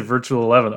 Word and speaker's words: Virtual XI: And then I heard someone say Virtual 11. Oh, you know, that sounds way better.